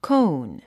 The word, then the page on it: cone: kóun; コーン